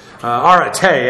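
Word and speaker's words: Arete (ἀρετή): Arete, actually, the accent is on the final syllable.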